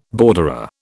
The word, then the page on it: borderer: British English